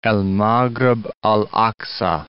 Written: El Maghreb al Aqsa

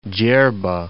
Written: Jarbah

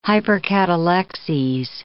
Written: hypercatalexes

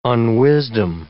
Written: unwisdom

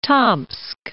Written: Tomsk

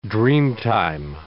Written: dreamtime

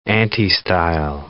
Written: antistyle

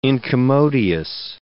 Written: incommodious

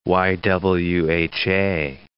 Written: YWHA

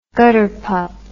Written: gutterpup

